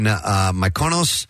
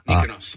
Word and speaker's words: Mykonos: The couple told People in a joint statement, we chose to celebrate our love by getting married at our villa in Mykonos. – Mykonos.